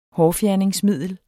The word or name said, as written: hårfjerningsmiddel